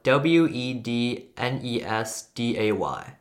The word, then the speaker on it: Wednesday: So Wednesday is spelled W E D N E S D A Y. The word D is completely silent. When you say Wednesday, you, you cannot hear any D inside.